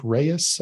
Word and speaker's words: Ries: And it's like one of the like quintessential like business books, by Eric Ries, I think is how you pronounce his name. R-I-E-S.